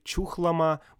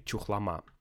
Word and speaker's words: Чухлома: Кстати, я вижу, что Википедия говорит, что э, есть два ударения. Можно говорить Чухлома, можно Чухлома. Такое иногда в России случается. Но ну, будем говорить Чухлома.